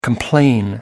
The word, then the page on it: complain: American English